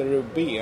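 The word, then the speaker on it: Rue B: Den heter Ruby alltså. som betyder gata B på franska. Men, men den uttalas Ruby, alltså som namnet Ruby.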